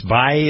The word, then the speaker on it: Bai: The two ladies were around him, and there's the guy who started BAI Drinks. Bai, is that how you pronounce it? – Yeah, Bai.